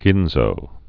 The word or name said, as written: ginzo